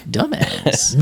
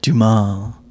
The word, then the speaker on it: Dumas: the Count of Monte Cristo by Alexander Dumas. That's what this is. He's like, it's pronounced Dumas.